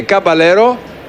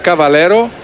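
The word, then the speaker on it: Caballero: Τρομερό αυτό, να λέγεται Καμπαλέρο, το οποίο προφανώς θα διαβάζεται Β, Καβαλέρο.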